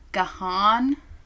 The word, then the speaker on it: Gahan: G-A-H-A-N. – Gahan?